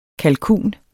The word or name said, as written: kalkun